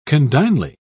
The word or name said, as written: condignly